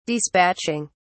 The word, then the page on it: dispatching: British English